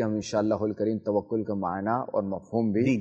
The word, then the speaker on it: توکل: دیکھیں صحیح تلفظ توکل ہے.